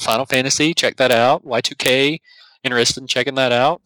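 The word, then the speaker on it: YIIK: That's how you say it. Yes. I was sincerely thought it was Yeek. – Yeek. – And it was like a sequel to Yogg. And I like, Yogg sounded cool. I'll play Yeek. – You know, the sad part is, I told him repeatedly it was Y2K, and he was like, no, it's not. You're saying it wrong, and I'm like, I'm pretty sure I'm not, but thanks, husband.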